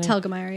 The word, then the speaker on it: Telgemeier: Oh, Telgemeier, is that how you say her name? – Telgemeier, yeah.